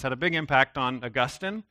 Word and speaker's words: Augustine: This is St. Augustine, or Augustine is how some people say his name, a really famous foundational thinker and theologian.